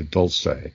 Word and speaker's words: Dulce: Back to the Dulce, that's how you pronounce it, by the way, Dulce.